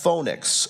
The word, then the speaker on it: Phoenix: To see from there if somehow they might reach Phoenix, which is pronounced Phoenix, a harbor of Crete facing northeast and southeast, and spend the winter there.